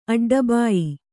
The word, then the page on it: ಅಡ್ಡಬಾಯಿ: aḍḍabāyi